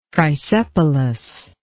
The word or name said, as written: trisepalous